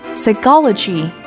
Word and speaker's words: psychology: Tuy nhiên có những trường hợp đặc biệt khi chữ cái PH được phát âm là F, phone, hay P không được phát âm như trong psychology. Psychology, tâm lý học, đánh vần là P-S-Y-C-H-O-L-O-G-Y.